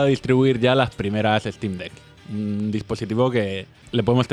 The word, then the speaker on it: Steam Deck: Steam Deck.